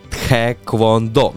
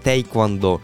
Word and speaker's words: тхеквондо: Сьогодні всесвітній день Тхеквондо. Я б хотів би додати, тому що ну сказати таке, що багато хто говорить тейквондо. Ні, тхеквондо.